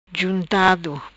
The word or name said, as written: Juntado